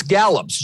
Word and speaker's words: Gallups: Yes, it's Gallups, G-A-L-L-U-P-S. It's like the Gallup poll, but with an S on it.